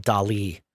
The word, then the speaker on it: DALL-E: Well, OpenAI, the company that has ChatGPT, also has another product called DALL-E. And I figured out that they're probably trying to pronounce like the artist Salvador DALL-E, but it's spelled DALL-E.